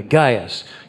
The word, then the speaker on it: Gaius: Gaius.